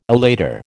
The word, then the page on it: elater: American English